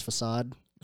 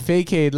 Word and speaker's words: facade: The, like, 2 weeks ago, didn't know how to fucking pronounce facade. – Bro, I'm such an idiot. I literally said fake-aid.